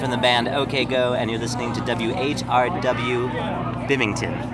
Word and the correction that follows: Binghamton: Is that right? – Binghamton. – Bimington. How do you say it? – Binghamton.